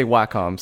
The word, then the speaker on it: Wacom: But yeah, we say Wacom, so there it is. – Do you? – But yeah, I mean, they're saying that's how we say it at the company.